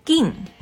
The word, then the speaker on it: Gin: 啊，你爸爸呢叫金，金在日文里面的发音，他那个片假名的那个呃拼音是 gin，是 g i n 这个发音。